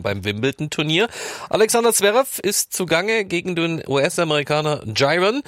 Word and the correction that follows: Giron: Dritte Runde, weiß nicht, ob ich den Namen richtig ausgesprochen habe, aber das weiß Matthias Kammann sicherlich besser. Wie sieht's denn aus für den Hamburger? – Ja, er spricht sich tatsächlich Marcus Giron und Alexander Zverev tut sich bisher so leicht, wie man das erwartet hat.